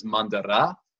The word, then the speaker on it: mandara: So mandalas, with the pronunciation in Japanese, is mandara.